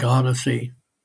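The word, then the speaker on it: Audyssey: But I think it's spelled as an audio, but they obviously, make it sound like Odyssey, with an O instead of an A-U-D,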